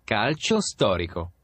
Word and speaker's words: Calcio Storico: Calicio, right? – Calcio Storico.